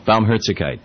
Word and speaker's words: Barmherzigkeit: Barmherzigkeit? – Yeah, Barmherzig. Barmherzigkeit.